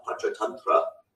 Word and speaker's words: Panchatantra: And you had the ancient tales of the Panchatantra, again, that's how you pronounce it, the discourse, the five Panchatantra, yeah.